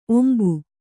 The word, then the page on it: ಒಂಬು: kannada